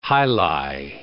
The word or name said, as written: jai alai